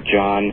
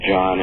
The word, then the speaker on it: Jahn: And the middle book is called The Second Coming of Science, and I devote a whole chapter to the landmark research of Robert John, and that's spelled J-A-H-N, Robert John, and Brenda Dunn and their colleagues at Princeton University.